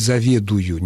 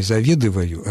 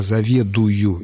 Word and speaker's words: заведую: А вот, допустим, глагол «заведовать» в первом лице настоящего времени единственного числа звучит «заведую», не «заведываю», а «заведую».